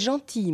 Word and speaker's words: gentil: Vous êtes gentil, monsieur. Dieses L ist stumm.